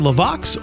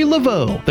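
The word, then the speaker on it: LeVox: And whether you pronounce it Gary LaVox or Gary LaVeau, the guitarist and vocalist for Rascal Flatts celebrates his 48th birthday today.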